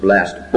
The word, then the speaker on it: blessed: The adjective, sometimes we pronounce it blessed, sometimes we pronounce it blessed. Both are right.